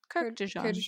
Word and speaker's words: Kurkdjian: Is that how we pronounce his name? – Yeah, Kurkdjian. Kurkdjian.